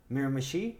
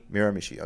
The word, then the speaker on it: Miramichi: I hope I'm saying it well. Miramichi, isn't it? Is that it? I mean the name of the town. Do I pronounce it right? – Miramichi? – Miramichi, okay.